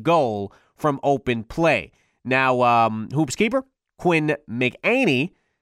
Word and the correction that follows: McEnany: McEnany, I think. Am I saying that right, Isaac? Can you pronounce that for me? Do you know what that is? – I think it's McEnany. – Yeah, that looks right.